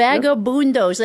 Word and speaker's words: Vagabundos: How do you say it? The Vagabundos, you got it right. – The Vagabundos.